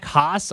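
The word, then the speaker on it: Hasz: But the other 2 that I think are maybe the most exciting of the transfers, Jack Haas, I don't know how to say his last name.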